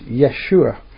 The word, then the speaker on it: Yeshua: Now, I should mention, the word Yeshua, we get the word Jesus, is just the Greek version of Yeshua. And we in English pronounce it Jesus. If you're in France, you pronounce it Yesu. But in Hebrew, the name is Yeshua.